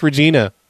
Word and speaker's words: Regina: Oh, no, it's pronounced Regina.